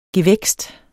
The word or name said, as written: gevækst